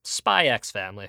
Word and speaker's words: Spy X Family: Ladies and gentlemen, we are back after watching 5 whole episodes of the hit 2022 anime, Spy Family, or Spy X Family if you prefer. And on that note, y'all, if you get upset over people pronouncing the X in the name, get over yourself.